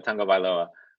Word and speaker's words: Tagovailoa: It's Tua Tagovailoa. Yeah, it's weird. It's not how it's spelled.